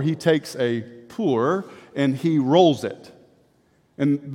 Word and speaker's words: Pur: Purim in the Hebrew, or Pur, P-U-R as it stands, but we pronounce it Pur, is the Hebrew word for dice.